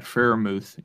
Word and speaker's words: Freiermuth: Freiermuth? I don't know how to say his name. – Pat Freiermuth, yeah.